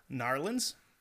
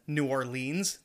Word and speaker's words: New Orleans: People that pronounce it Narlins. And then New Orleans, it's like, they're both right, but neither one of them sound okay.